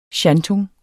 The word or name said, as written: shantung